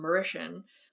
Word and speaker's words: Mauritius: But then another, like the article that I read by the writer from Mauritius. That is how you say that word.